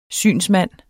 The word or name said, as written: synsmand